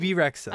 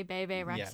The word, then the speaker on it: Bebe Rexha: BB Rexha. – Okay. I was about to say Bebe Rexha. – Yeah. No, it's BB.